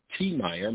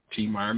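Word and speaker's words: Tiemeyer: If you want to go to YouTube and find us, just go ahead and put The Message of Life, T. Meyer. My name is spelled T-I-E-M-E-Y-E-R, T. Meyer McCain, or Tasila, T-O-S-I-E-L-A. So you put T. Meyer and Tasila McCain, The Message of Life, and you YouTube us, and you can see a lot of our other programs.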